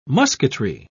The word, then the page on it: musketry: British English